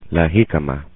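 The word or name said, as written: jícama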